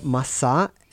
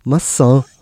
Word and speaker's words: Massa: Oder Apfel heißt geschrieben Massa, M-A-C mit so einem Unterstrich und dann A, Massa einfach. Und die spreche ich das einfach so, ja, so durch die Nase, Massa.